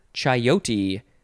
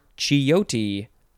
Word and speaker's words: chayote: Next is chayote or chiyote or chiyote. You can really just change the vowels however you want. It is spelled C-H-A-Y-O-T-E,